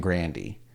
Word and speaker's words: Grande: Who apparently you pronounce her last name Grandy.